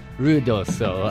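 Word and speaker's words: Ruidoso: For all of us non-Spanish speakers, could you, one, pronounce it properly? And two, could you tell me what it means? – Rudoso. – Rudoso. I'm sorry. Scusi.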